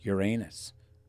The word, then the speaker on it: Uranus: These lips come up and you hit the thing and it says straight up Uranus. – Wow. Uranus. That's how the Google people say it.